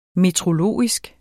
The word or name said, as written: meteorologisk